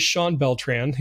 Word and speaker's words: Beltran: But his name is Shawn Beltran.